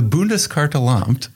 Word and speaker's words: Bundeskartellamt: So, Germany, for instance, uh, I'm going to pronounce this wrong the Bundeskartellamt, very good, the German competition authority, actually took action against Facebook not too long ago.